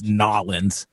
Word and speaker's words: New Orleans: Yeah, I say it how it's spelled, but I know it's Nawlin's.